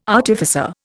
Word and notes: artificer: Indian

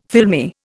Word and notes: filmy: Indian

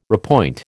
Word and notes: repoint: American English

